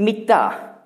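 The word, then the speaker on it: Mitta (מִטָּה): Auf Hebräisch schreibt man Stab gleich wie Bett. Matthä. Spricht man es allerdings aus, wenn es Stab heißen soll. Andere Vokale werden eingesetzt in die Konsonanten. Und für Bett Mitta.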